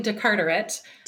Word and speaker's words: DeCarteret: So my name is Ashley DeCartwright. – DeCartwright. – Just exactly like it looks.